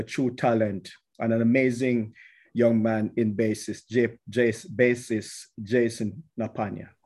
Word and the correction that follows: Napagna: Hope I said it correctly. – Napayon. – So the Y is active. Napayon.